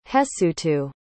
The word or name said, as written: hesutu